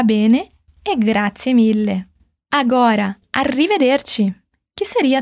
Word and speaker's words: arrivederci: Arrivederci. E esse ti do final é ti de tia mesmo. O CI em italiano tem essa pronúncia, ti.